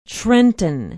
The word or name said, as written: trenton